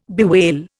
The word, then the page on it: bewail: Indian